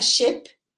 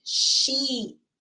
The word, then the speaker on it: sheep: Or do you guys say sheep? Sheep. Remember, this double E pronounces the E, and you do it when you make your mouth smile like a bit.